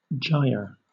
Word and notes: gyre: British English

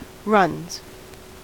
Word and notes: runs: American English

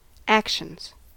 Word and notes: actions: American English

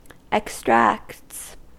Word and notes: extracts: American English

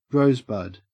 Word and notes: rosebud: Australian English

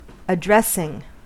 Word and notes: addressing: American English

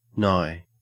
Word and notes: nigh: Australian English

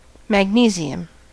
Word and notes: magnesium: American English

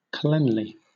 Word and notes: cleanly: British English

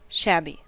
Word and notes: shabby: American English

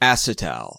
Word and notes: acetal: American English

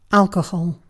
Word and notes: alcohol: British English

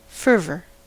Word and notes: fervor: American English

